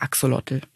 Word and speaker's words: Axolotl: Das kommt aus der aztekischen Sprache und wird wahrscheinlich eher so ein bisschen Axolotl, irgendwie sowas ausgesprochen. Ich kann es leider gar nicht richtig. Da gibt's auch lustige YouTube-Videos dazu. Mhm. Aber in der Wissenschaft und im allgemeinen Sprachgebrauch sagen wir Axolotl.